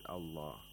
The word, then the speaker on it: الله: Qulillah, the word Allah is pronounced with tarqiq because there is a letter with a kasra before the word Allah.